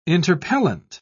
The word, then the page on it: interpellant: British English